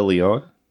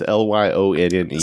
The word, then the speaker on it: Lyonne: Natasha Lyonne. Is that how you say her name? – Yeah, that sounds right. – Or is it Lyon? It's Lyonne.